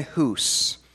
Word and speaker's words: Hus: So we will first begin with Jan Hus. Some people will look at his name and it can be looked at as John Huss, but I would argue that his name is Hus. It rhymes with goose.